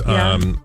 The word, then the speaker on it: Ye: Yeah. Our wonderful Twitter listener, What Sleep Though, says, y'all got to stop pronouncing ye like ye. Ye is pronounced like ye in Kanye.